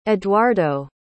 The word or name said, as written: edwardo